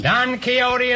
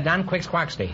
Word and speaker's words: Don Quixote: Don Quixote is right. until I was until I was 40 years old, I pronounced that Don Quix Quaxty.